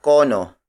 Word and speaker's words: kono: Bola, the Tagalog word for ball, is spelled as B-O-L-A. Again, the Tagalog word for sphere is espera. Up next, we have cono. Cono. Cono. Cono is spelled as K-O-N-O.